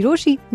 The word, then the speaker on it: Rochie: मेरा नाम है मिर्ची रोशी, नाम की स्पेलिंग है ROCHIE, so do whatever you have to do, लेकिन कहीं मत जाना जाना, क्योंकि वापस तो यही है आना।